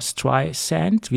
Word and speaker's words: Streisand: weil Siri hat es bis vor ein paar Monaten mit Z ausgesprochen und nicht mit S wie Sand, weil es heißt ja Barbara Streisand, wie der Sand am Strand, und das hat sie doch zutiefst geärgert.